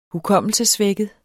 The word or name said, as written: hukommelsessvækket